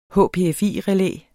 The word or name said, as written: HPFI-relæ